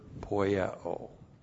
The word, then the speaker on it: poieo: And then we have the verb poieo. P-O-I-E long O, poieo.